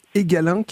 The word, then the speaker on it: Egalink: Alors je le prononce sans doute mal, hein, Egalink, c'est ça ? – Oui, c'est comme ça.